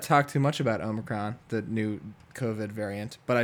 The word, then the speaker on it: Omicron: Omicron. – Omicron. – Or is there an N in there? It's not no. Omicron. – No, it's Omicron.